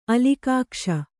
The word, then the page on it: ಅಲಿಕಾಕ್ಷ: alikākṣa